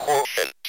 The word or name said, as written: quotient